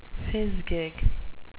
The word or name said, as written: fizgig